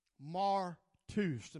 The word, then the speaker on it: martus: Martus. And by the way, I'm preaching about called to be a witness. Called to be a witness. The phonic pronunciation is martus. Look at somebody and say martus.